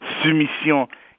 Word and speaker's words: Soumission: The point of this novel, which I insist on calling in its, you know, snooty French pronunciation, soumission, even though I read it in English, is really interesting.